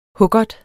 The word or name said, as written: hukkert